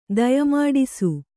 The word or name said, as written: ದಯಮಾಡಿಸು